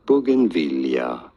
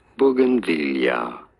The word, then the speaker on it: Bougainvillea: Bougainvillea, you do want to stress on the third syllable, the V syllable. Bougainvillea.